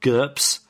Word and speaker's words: GURPS: GURPS. Rhymes with slurps.